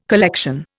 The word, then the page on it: collection: Indian